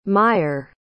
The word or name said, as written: meier